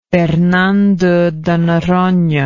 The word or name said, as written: Fernando de Noronha